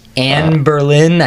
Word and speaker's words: Anne Boleyn: Anne Boleyn, I think it's correct.